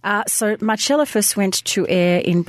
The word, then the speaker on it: Marcella: It is called Marcella, or as they pronounce it, Marcella.